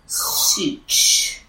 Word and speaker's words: Ciuc: How would you say that? C-I-U-C. – Sitch. Sitch.